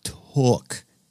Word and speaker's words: toque: Pronounced took. Took.